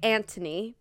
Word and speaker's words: Anthony: So I think in the show they say Antony because of their accent. But like obviously on paper it's spelt like A-N-T-H-O-N-Y.